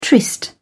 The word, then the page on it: tryst: British English